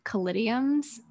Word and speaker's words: caladiums: I got to is it Caladiums? I think that's how you say it. – Yeah, yes.